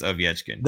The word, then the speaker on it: Ovechkin: If you're Dan Ruzanowski, it's Ovechkin. – That's the actual pronunciation in Russian.